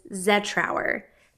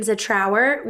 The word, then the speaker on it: Zetrauer: But the correct way to say my last name is Zetrauer. Emphasis on the Z. – Shoo! I've been saying it right the whole time. Yay! – Now, a lot of people around here do call me Jin Zetrauer with the emphasis on our.